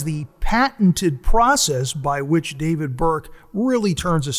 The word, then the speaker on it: David Burke: Well, we're here at Double Barrel Steak by David Burke, and I said it correctly.